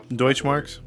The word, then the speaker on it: Deutschmark: The civil case concluded, and the court ordered Mercerick to pay Michael's 7,000 Deutschmark money. What's the word? – Deutschmarks. – How to pronounce it. There you go, Deutschmark.